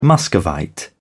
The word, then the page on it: muscovite: British English